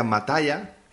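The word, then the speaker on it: Musgraves: Se trata de Casey Mata Matea, se pronuncia Mataya, Casey Mataya, que acaba de publicar su 16 álbum de estudio, coproducido junto a Gary Paxosa.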